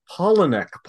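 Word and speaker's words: Palahniuk: He was a teaching assistant for writing workshops run by Chuck How does Chuck pronounce his last name? – Polinek.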